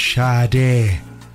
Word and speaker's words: Sade: Sorry, my producer, Monsignor Murphy's in my ear saying it's pronounced Sade. So that's Sade with the Sweetest Taboo. You can listen to your Sade with a glass of lemonade.